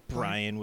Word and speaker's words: Brien: Brian with an E. – Yeah, it's like O'Brien without the O.